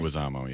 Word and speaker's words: Leguizamo: Leguizamo, yeah.